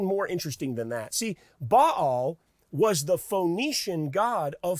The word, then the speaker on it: Baal: Ahab's greatest offense to God was that he even encouraged Israel, the people of God, to worship a false god named Baal. Sometimes you hear it pronounced Baal, but really you'll hear me say Baal.